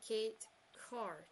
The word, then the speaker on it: Kate Carr: So, without further ado, and fingers crossed I do not butcher any names here, please welcome Liz and Kate Carr.